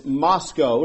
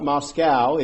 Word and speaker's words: Moscow: We learned that it's Moscow, not Moscow, even though it's spelled that way. They said it's like Idaho, it's Moscow.